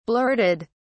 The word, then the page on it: blurted: British English